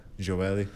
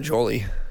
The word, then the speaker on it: Joely: Joely. Definitely not Jolie. Jolie. Joely maybe.